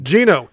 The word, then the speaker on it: Geno: I mean, Geno, they said it out loud. We now know it is Geno. Hells yeah. Don't have to worry about how to pronounce that anymore.